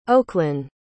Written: Oaklyn

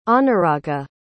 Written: anuraga

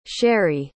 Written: sheree

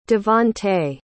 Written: devontae

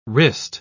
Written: wrist

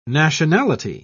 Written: nationality

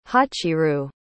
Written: hachirou

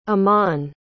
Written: amnon

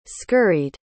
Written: scurried

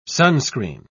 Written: sunscreen

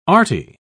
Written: arty

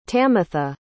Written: tamitha

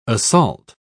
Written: assault

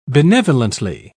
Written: benevolently

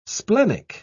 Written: splenic

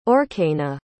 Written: aurkena

